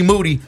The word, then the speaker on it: Moody: Yo, ladies and gentlemen, we got G Moody, last name rhyme with duty, y'all.